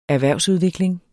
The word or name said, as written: erhvervsudvikling